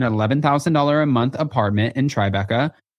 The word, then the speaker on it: Tribeca: Are you going to laugh at how I say Tribeca? – No, you said it. Okay, so no, it's not that you say it wrong. It's Tribeca. – Yeah. – But sometimes you're like, Tribeca.